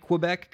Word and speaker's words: Quebec: Quebec. Did I pronounce that right? Quebec? – Yes, you did, my friend.